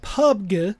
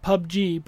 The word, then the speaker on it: PUBG: Pubg, which some people pronounce it PUBG, but it's Pubg, which is Player Unknown's Battlegrounds.